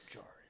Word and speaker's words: Djarin: Yeah, you don't pronounce the D in the second in the last name. Din Djarin.